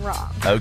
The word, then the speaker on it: Rahm: Is that John Rahm? Is that how you pronounce it? – John Rahm. – Okay, thank you.